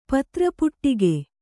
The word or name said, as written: ಪತ್ರಪುಟಿಕೆ